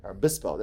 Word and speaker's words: Obispo: Prior to moving into real estate, Brandon obtained his degree from the School of Business and Engineering from Cal Poly San Luis, I'm gonna butcher this, Obispo. – You got it. – Obispo, there you go.